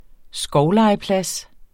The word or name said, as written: skovlegeplads